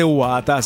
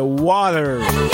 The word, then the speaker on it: water: Es que conste, no se dice guata, se dice water. Water. Aquí en Colombia decimos guata.